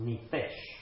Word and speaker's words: nephesh: It is spelled, if you would like to look it up, N-E-P-H-E-S-H, pronounced nefesh.